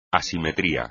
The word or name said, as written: asimetría